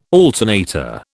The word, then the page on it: alternator: British English